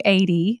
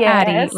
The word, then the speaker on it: AD: I heard that your friends call you AD, not Addy, right? – Not Addy, no. The long A. – Yeah, long A.